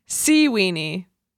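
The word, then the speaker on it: Seaweanie: I thought you were mispronouncing this, but it really is S-E-A-Weenie, W-E-A-N-I-E.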